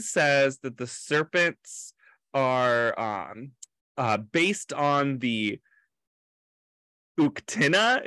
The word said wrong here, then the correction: Uktena: Is that, I think that's how they pronounce it. – I thought it was Uktena. – Uktena, thank you.